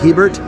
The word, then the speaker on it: Hebert: And that last name is spelled H-E-B-E-R-T. Spelled like Hebert, but pronounced like Hebert.